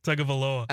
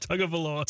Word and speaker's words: Tagovailoa: Tagovailoa. – I don't think Chad said it right. On the, Tagovailoa.